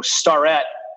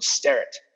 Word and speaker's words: Starrett: You know, Starrett is a relatively new, like 2-generation-old way of pronouncing the word Starrett, which is an Irish name.